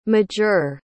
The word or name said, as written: majeure